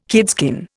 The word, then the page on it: kidskin: Indian